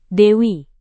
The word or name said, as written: dewi